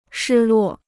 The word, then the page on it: 失落: shī luò